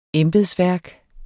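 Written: embedsværket

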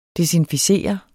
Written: desinficere

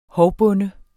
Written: hovbonde

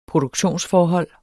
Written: produktionsforhold

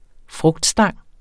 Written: frugtstang

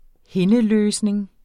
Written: hindeløsning

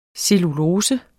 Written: cellulose

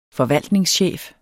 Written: forvaltningschef

